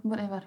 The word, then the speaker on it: Bon Iver: A, bo jak se vyslovuje? – Jo, Bon Iver.